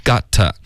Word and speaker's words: got: Got, 不是 get, OK? Got, 嘴巴要张开 got.